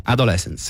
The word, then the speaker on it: Adolescence: però lui è già autore di Adolescence. Si pronuncia così? – Adolescence, sì.